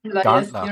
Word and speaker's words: Gandler: It's Gandler.